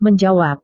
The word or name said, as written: menjawab